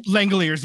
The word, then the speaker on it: Langeliers: Well, shout out to your 2022 Oakland A's top trade prospect, Shane Langeliers, I think is how you pronounce his last name. – Langeliers, yeah.